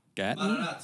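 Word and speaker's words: Gaten: How do you say his name? – Gatton.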